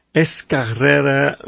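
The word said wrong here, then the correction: Escárrea: River. If I pronounce that right? – Almost. It's pronounced Escarria.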